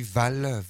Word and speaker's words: Hval: Et puis juste avant, Jenny Val, Val s'écrit avec un H devant, H-V-A-L.